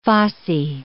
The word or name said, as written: fossae